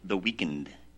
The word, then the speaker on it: The Weeknd: The Weeknd, as in he is a weakened.